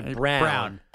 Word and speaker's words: Braun: We know how it's spelled. For some reason, he says it Brown.